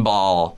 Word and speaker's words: Bahl: And look up Kevin Ball. – All right. – Last name is spelled B-A-H-L. – Got it. Kevin Ball, everybody.